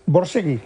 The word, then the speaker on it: Borzage: Dile cómo se dice Borzage. – Borsegui. – ¿Cómo? – Borsegui. – Como Borsegui. Borsegui.